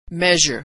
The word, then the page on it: measure: zh